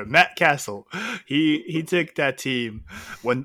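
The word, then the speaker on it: Cassel: It's actually Matt Castle, okay? – No, Castle is what you build, like sandcastle. – I know, but his name is pronounced Matt Castle, not Cassell.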